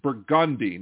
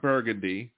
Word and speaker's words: Burgundy: The street is Burgundy, not Burgundy.